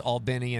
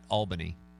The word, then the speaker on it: Albany: Because it's Albany and not Albany. If there's a word that looks like it should be pronounced one way and it's not even close, that place is in Georgia.